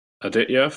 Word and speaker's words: Aditya: Okay, so looks like we've got apologies if I butcher your name here, but Aditya from Persistence. – Hey, how you going? That was actually a good pronunciation, so good job.